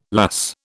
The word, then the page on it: lass: British English